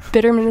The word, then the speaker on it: Bitterman: Last name is spelled S-A-R-D-I-S. – Guys, we're all really original here because mine is Abby_Bitterman. Bitterman is spelled B-I-T-T-E-R-M-A-N.